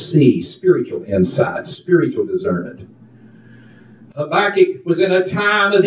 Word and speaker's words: Habakkuk: Habakkuk. Is that how you pronounce it? – Habakkuk. – Sounds good.